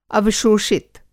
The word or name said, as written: अवशोषित